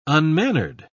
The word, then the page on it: unmannered: British English